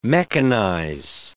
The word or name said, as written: mechanize